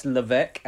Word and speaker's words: Levesque: He says, "Peeve, Paul Levesque, pronounced Levesque as it's French Canadian, was referred to by his actual name by CM Punk when they were having a Barney one time.